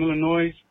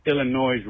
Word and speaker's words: Illinois: Even though she's from Illinois, she roots for the Go Pack Go team. Oh, wait. I pronounced Illinois wrong. Illinois.